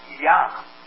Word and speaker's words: Jah: Now, you'll see it written in your Bible in the King James as Jah, J-A-H. However, it's pronounced Yah. Since there's no J sound in the Hebrew whatsoever, and the New King James Bible restores the correct rendering that I've got here, Yah, Y-A-H.